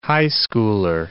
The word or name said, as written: high schooler